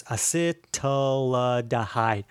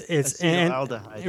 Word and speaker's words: acetaldehyde: I keep saying that wrong because it's acetaldehyde. – Sound it out. – It's acetaldehyde.